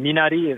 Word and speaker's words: Minari: If you say Minari, that's a lot of people on set say that, and that's great. And Minari is Korean. We say Minari. Almost the R is almost like a D.